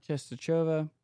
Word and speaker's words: Częstochowa: Is that how you say it? – I think Częstochowa. – Częstochowa.